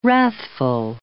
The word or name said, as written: wrathful